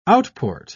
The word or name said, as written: outport